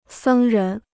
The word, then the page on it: 僧人: sēng rén